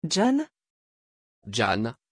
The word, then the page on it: gian: French